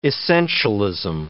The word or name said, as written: essentialism